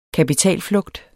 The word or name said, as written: kapitalflugt